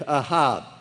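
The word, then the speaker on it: ahab (אהב): But it's ahab, aleph, hey, bet. Pronounce A-H-A-B with low pronunciation, ahab. It's not hard to do at all. Ahab.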